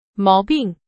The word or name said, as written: 毛病